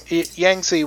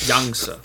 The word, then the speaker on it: Yangtze: Yangtze would be like the western pronunciation of it. – The western butchering of it, so how is it correctly pronounced then? – Yangtze.